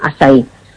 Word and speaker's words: açaí: Si lo queréis buscar escrito, que se escribe A con la cedilla, como si fuera una portuguesa, y se llama eso Acai orgánico, y se pronuncia açaí.